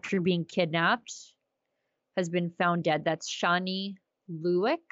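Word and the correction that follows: Louk: I hope I'm saying that right. – I think it's Loke. Yeah. – Loke.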